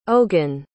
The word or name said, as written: ogen